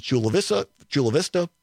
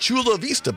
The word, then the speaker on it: Chula Vista: They combine an English pronunciation with a Spanish. So Chula in Spanish would be Chula. That's how you say it. Vista in Spanish would be Vista. So right. it should be like Chula Vista, baby. But no, it's Chula Vista. Anyway, but the key word for the 1 o'clock hour, Chula, C-H-U-L-A.